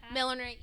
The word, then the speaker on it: millinery: And then I was, I think I took like a millinery class. Did I say that right? Milliner. – I don't, it's hats. – Hat making. – Yeah. Hat. – Millinery. Yeah.